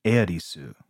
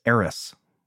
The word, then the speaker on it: Aerith: Like the, the classic example is that in Final Fantasy VII, the character Aerith, in Japanese, it's pronounced Eirisu. And so in Final Fantasy VII, when it was ported over to uh, the West, her name popped up as Eris with an S, even though it is Aerith, right?